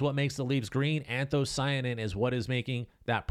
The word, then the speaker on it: anthocyanin: I had to print that word out because I've been saying it wrong. I've just been calling it anthos because I wasn't sure how to say the last part because I've never had to say it out loud before, honestly, until today. But what you're seeing is anthocyanin.